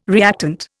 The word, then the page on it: reactant: Indian